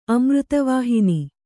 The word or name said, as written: ಅಮೃತವಾಹಿನಿ